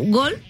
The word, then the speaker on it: Goal: O sea, bueno, se pronuncia como Gol, pero es Goal.